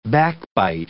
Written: backbite

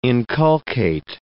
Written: inculcate

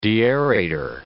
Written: deaerator

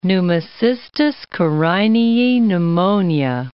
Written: Pneumocystis carinii pneumonia

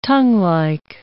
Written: tonguelike

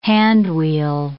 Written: handwheel

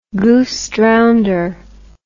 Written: goosedrownder

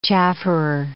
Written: chafferer

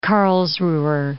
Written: Karlsruher

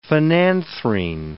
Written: phenanthrene

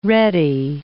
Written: ready

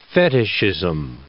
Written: fetichism

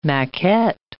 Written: maquette

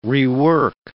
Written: rework

